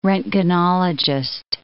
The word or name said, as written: roentgenologist